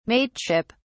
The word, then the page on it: mateship: British English